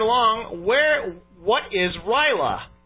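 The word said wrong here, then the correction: R'lyeh: You mean Rilea.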